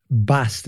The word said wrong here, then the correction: Båstad: is that how you say it? – Båstad.